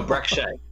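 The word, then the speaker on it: Brek Shea: I think it's pronounced Breek Shea. No, Breck Shea.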